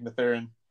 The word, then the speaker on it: Mathurin: Benedict Mathurin? – Yeah, I think that's right. Benedict Mathurin.